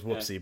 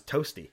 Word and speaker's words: Toasty: Oh, we all thought it was whoopsie, but it's toasty, as a matter of fact.